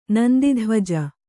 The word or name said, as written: ನಂದಿಧ್ವಜ